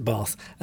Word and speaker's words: Bath: I'm very aware of my northern accent when I say that one word, Bath. You have to put an R in it for me to pronounce it Bath.